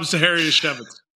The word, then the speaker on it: Zachariasiewicz: Rob Zachariasiewicz. – See, and it rhymes with Manischewicz, so Zachariasiewicz. So that's the only way I can remember this, because I thought it was Zachariasiewicz, but no, it's not that.